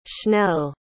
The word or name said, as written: schnell